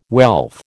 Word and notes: wealth: American English

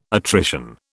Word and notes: attrition: British English

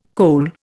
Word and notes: kohl: Indian

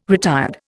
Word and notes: retired: Indian

